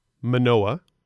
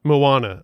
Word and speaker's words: Moana: The second film is Manoa. Third... – What's Manoa? Moana? – Moana.